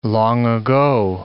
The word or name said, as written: long-ago